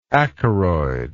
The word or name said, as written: acaroid